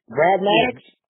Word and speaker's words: Brad Maddox: Brad Maddox? – Yeah.